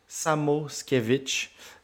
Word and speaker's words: Samoskevich: Excusez-moi, je vais me concentrer 2 secondes pour bien prononcer son nom. C'est Samoskevich, un allié droit qui pourrait venir puis tenter un peu de remplir ce trou-là.